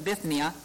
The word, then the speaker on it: Bithynia: So their first impression was they wanted to go to Bithynia, that's how you pronounce it, Bithynia, and Asia.